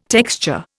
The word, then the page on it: texture: Indian